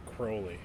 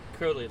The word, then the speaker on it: Crowley: Crowley, right? – Crowley, like holy. – Yeah, it's because a lot of people mispronounce it as Crowley. – Oh, yeah, it rhymes with holy.